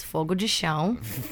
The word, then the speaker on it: Fogo de Chão: He pronounced it really American. It's Fogo de Chão. – Fogo de Chão.